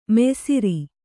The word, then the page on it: ಮಯ್ಸೂರ್: maysūr